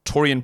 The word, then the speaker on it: Taurean: Oh, it sounds like taurine. I went, Yeah, all right. So what is it? The amino acid legend, Taurean Prince.